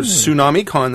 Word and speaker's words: Tsunami: So you can go to Tsunami Con, that's with a T, which is silent.